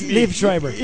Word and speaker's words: Liev Schreiber: Te faltó no mira, pero es Liv Schreiber.